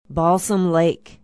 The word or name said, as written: balsam lake